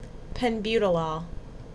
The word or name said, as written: Penbutolol